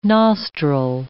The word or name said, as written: nostril